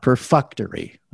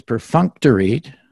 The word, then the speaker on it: perfunctory: So for their benefit, first of all, it is not perfunctory, which we heard some of you say. It is perfunctory. Learn to pronounce perfunctory.